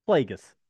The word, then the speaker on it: Plagueis: Plagueis. – Yeah, Plagueis.